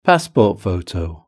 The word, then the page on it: passport photo: British English